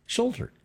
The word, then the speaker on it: Schulter: Schulter. I can't even say it right. – He's not even. I don't even know what he's talking about. He's not close. – S-C-H-U-L-T-E-R. – Shoulder.